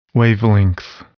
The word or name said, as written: wavelength